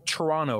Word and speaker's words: Toronto: No T, no second T, Toronto. I'm trying. I'll fail many times. And because it's Peacock, to make everyone feel more comfortable, I'll be in the booth for the Toronto weekend.